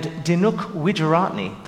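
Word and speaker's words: Dinuk Wijeratne: So we're going to focus mainly on tabla and Dinuk Widjuratne, the composer. Did I say that right? – Yes.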